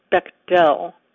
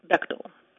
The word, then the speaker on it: Bechtel: My last name gets mispronounced frequently, usually as Bechtel, but I pronounce it Bechtel. If you need a tip, just remember that it rhymes, most unfortunately, with rectal.